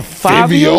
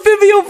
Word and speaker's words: Fivio: That's not how you say his name? – No, not Fabio. Fivio.